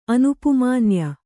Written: ಅನುಪುಮಾನ್ಯ

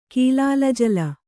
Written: ಕೀಲಾಲಜಲ

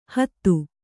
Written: ಹದ

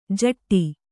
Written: ಝಟ್ಟಿ

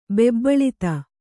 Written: ಬೆಬ್ಬಳಿತ

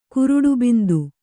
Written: ಕುರುಡುಬಿಂದು

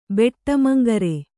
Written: ಬೆಟ್ಟಮಂಗರೆ

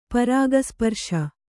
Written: ಪರಾಗಸ್ಪರ್ಶ

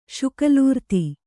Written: ಶುಕಲೂರ್ತಿ

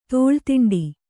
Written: ತುಲಾದಂಡ

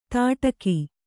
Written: ತಟಕಿ

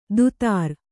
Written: ದುತಾರ್

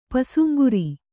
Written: ಪಸುಂಗುರಿ

